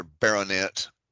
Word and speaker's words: baronet: Sir Bulverton Redwood, he's another baronet. I guess it rhymes with bayonet.